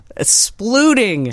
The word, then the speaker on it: sploot: Sploof. – Splooting. – Sploot.